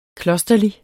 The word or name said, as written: klosterlig